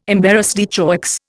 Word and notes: embarras de choix: Indian

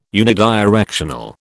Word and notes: unidirectional: British English

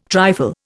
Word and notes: trifle: Indian

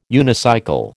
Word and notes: unicycle: American English

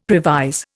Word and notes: previse: Indian

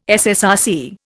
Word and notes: SSRC: Indian

